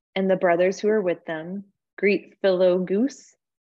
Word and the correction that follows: Philologus: nope, I said that wrong, Philologus,